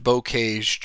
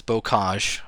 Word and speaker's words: Bocage: It is not pronounced Bocage, Drew. It is Bocage, Frenchy up in here.